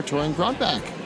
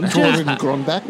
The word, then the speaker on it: Torunn Grønbekk: And we are here with writer extraordinaire Torunn Grønbekk.